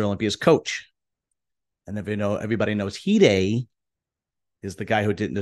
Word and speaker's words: Hide: I kept calling him Hade, and his name is Hide.